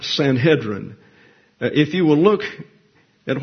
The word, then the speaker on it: Sanhedrin: And that particular E, the segol, is pronounced as the E in bed. Therefore, the pronunciation in Hebrew is Sanhedrin. Now, in English, you have two accepted pronunciations, Sanhedrin and Sanhedrin. I go with Sanhedrin because that's the Hebrew pronunciation. I think we should stay with them. You can use Sanhedrin if you like, but the Jews say Sanhedrin, so there you go.